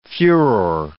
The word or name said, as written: furor